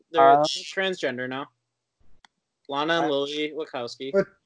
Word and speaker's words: Wachowski: it's wachowski and I'm just, I'll bone up on my pronouns, see what they re- see how they identify, and then go.